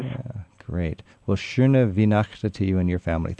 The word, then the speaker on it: Schöne Weihnachten: Schöne Weihnachten. – Schöne Weihnachten. – Schöne Weihnachten.